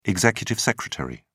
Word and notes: executive secretary: British English

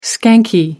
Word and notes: skanky: American English

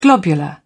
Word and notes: globular: British English

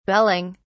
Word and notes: belling: British English

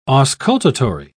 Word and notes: auscultatory: British English

